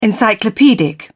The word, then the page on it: encyclopedic: British English